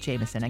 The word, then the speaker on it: JMSN: This artist is called Jameson. Again, J-M-S-N, pronounced Jameson.